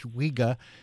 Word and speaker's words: Vega: So V-E-G-A should be pronounced Vega.